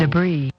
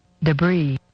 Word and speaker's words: debris: Hang on, we'll tell Debris. – Them. – Play it again. – Debris. – There's no S.